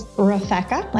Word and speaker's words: Refka: It is Refka, like Rebecca.